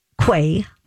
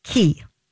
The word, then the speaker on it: Quay: So then we go to one of my favorites, Chrissy Teigen, and she just launched a new sunglass collaboration, and you might think it is pronounced Quay. – Mm-hmm. – It is pronounced Key.